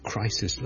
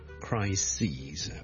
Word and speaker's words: crises: E-S 上一集讲的最常见的例子就是 crisis C-R-I-S-I-S 一个危机 A big crisis A number of crises、 okay? 留意个发音串字当然是不同的发音是不同的 crisis 和 crises 另外还有一件事上集没跟大家讲就是 crisis 最尾的 S 音是 S 来的所以个 sis 是短的而 crises S-E-S 呢就会变咗后面最尾嗰个 S 咧系 Z 音嚟嘅，所以 crises。